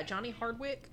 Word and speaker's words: Johnny Hardwick: Johnny Hardwick. Am I saying that right? Yeah, Johnny Hardwick, who's the voice actor for Dale Gribble from King of the Hill.